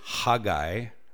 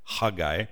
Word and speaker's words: Haggai: But before we do that, his name in Hebrew, Haggai, it's spelled with a C-H, and so it's Haggai. Haggai.